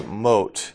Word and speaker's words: Mot: It talks about death, and death was often personified as the god Mot, M-O-T. Even though it's pronounced Mot.